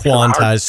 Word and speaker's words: quantized: Hopefully with the younger people, if they're into that, you know, I remember when the quantumized, I always say that word wrong, you know, like quantumization or I always say it wrong. – It's quantized, snapping to the grid, whatever, yeah.